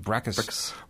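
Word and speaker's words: Bricusse: You know, he generally wrote the music, and Mr. Bricus, that's how you pronounce it, Bricus wrote the lyrics.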